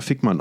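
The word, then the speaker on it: Vikman: Weil sie mit V geschrieben wird, aber es ist natürlich eine Steilvorlage. Ich hätte sie selbstverständlich auch Erika Fickmann ausgesprochen.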